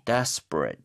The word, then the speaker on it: desperate: desperate. 이렇게 해서 ate가 동사 빼고는 다 어에 가깝게 발음하는 게 맞죠. 그렇죠? – 네, 맞아요.